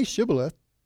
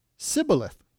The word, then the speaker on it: Shibboleth: They couldn't pronounce the H, so they pronounced it Sibboleth. You know how in our country, different parts of the country, you have different dialects and different accents and stuff like that? Well, same thing happened there, and the people of Ephraim couldn't pronounce the SH. So they couldn't say Shibboleth. They would say Sibboleth, and that was the key.